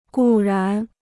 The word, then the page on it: 固然: gù rán